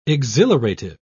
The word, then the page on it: exhilarative: British English